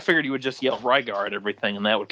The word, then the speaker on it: Rygar: Rygar.